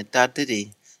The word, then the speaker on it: Dadiri: Can I just say, what's the correct pronunciation of it? Because I've read a few different pronunciations on it. How is the word supposed to be said? – Dadiri. – Dadiri. – Yeah, Dadiri. You know that song, do a diddy diddy dum diddy do? – Yeah, that like that?